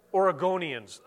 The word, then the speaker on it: Oregonians: It is something that is foreign to the vast majority of Americans, the vast majority of Washingtonians or Oregonites. Oregonians? How do you say it? Oregonians. I knew that.